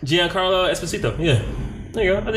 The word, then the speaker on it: Esposito: Esposito.